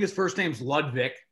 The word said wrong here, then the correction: Ludovic: Last name is Sholinian. Sholinian. My tongue doesn't do that, but it's pretty close. I'm pretty sure it's Ludovic.